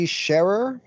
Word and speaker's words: Sherer: so my name's Benji Sherer, pronounced like someone who shares.